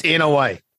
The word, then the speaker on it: Inoue: I think I've said Inoue, by the way, four different times oh, with four different pronunciations. Inoue. – Yeah. – It's Inoue. Inoue. No relation to the monster, but it's Inoue. uh,